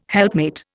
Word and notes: helpmate: Indian

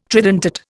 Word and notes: tridentate: Indian

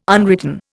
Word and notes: unwritten: Indian

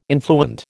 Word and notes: influent: American English

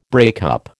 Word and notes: breakup: American English

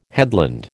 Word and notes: headland: American English

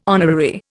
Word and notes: honorary: Indian

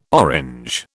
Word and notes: Orange: British English

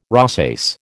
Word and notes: rosace: American English

